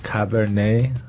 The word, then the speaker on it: Cabernet: So one of the most common types of red wine probably is Cabernet Sauvignon. – Right, you see that a lot. – 这个听起来有点难念，然后也不像英文。 – Because it's French. – Exactly. 那这个 Cabernet Sauvignon 就是加本利苏维翁，对吧？这样不知道。 – Of course. – 你把这个读音再来跟我们慢慢的再读一遍，好不好？ – Cabernet.